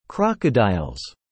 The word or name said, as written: Crocodiles